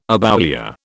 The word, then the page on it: aboulia: British English